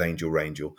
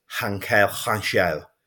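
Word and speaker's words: Ángel Rangel: can't pronounce it as Angel Rangel, so I just said, could you help me with this pronunciation, please? And he said, it is Hankel Rangel, and I said, I can't say that because it's just not a Derby way to say that.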